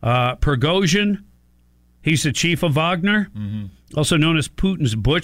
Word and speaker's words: Wagner: So this mercenary group that works on behalf of Putin, this Wagner group, it looks like Wagner. – Right. – But it's pronounced a Wagner, German, right?